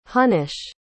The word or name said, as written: hunnish